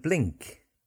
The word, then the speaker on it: blink: Bling and blink. Bling is an old fancy cheap jewellery. Lots of bling. B-L-I-N-G. Bling. And then blink. B-L-I-N-K. And get the K sound at the end to distinguish.